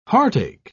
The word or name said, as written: heartache